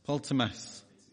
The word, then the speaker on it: Ptolemais: And when we had finished our voyage from Tyre, we came to, and this is another one of those, PT, how do you say that? PTOL. Ptolemais.